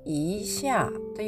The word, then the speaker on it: 一下: 発音記号は、イーシャーというふうに発音します。